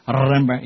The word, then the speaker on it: Rembrandt: Rembrandt. You got to roll your R's, man.